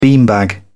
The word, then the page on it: beanbag: British English